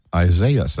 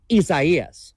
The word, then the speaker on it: Isaias: And we thought it would have been pronounced like this. – Isaiah's. – That's how we were saying it yesterday. – And that's what a computer thinks as well. But a foreigner says, this storm formed this morning. Isaias.